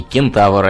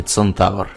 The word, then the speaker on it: кентавр: И кентавр, а не центавр. – Да, вот, например, э, есть правило такое. Для астрономических объектов мы говорим на латинский манер. Цербер, альфа центавра, а не кентавра.